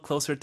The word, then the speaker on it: closer: Everyone a little closer together. – Everyone a little closer together. 자, 이때 closer 해서 반드시 이 비교급으로 말씀하셔야 된다는 겁니다. – Right. 쓰 발음이죠.